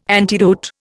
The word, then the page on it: antidote: Indian